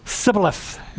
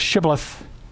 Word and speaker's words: Shibboleth: If their regional accent caused the Ephraimites to mispronounce the word and say Sibboleth instead of Shibboleth, they died.